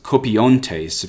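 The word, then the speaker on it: kopiontes (κοπιῶντες): The word is kopiontes. If you want to spell it in the Latin font, not the Cyrillic, it's K-O-P-I-O-N-T-E-S.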